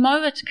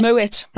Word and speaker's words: Moët: So, most of us will just say Mut. – Yeah. Oh no, that's true. Mut like but. But no, a lot of people think that that they must pronounce it the French way and go Moe. – Moe. – But meanwhile, the guy, the family. – It's actually Moet.